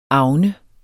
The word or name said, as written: avne